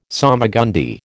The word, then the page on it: salmagundi: American English